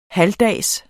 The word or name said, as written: halvdags